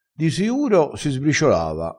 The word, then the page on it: manca un pelo: dialettale